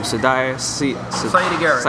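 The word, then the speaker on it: Siedah Garrett: I think at the time, I'm still mispronouncing her name, Sadia Garrett.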